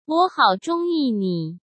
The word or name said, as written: wo hao zhongyi ni